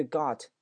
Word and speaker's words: got: 注意 got T 不发音，注意 to 的略读。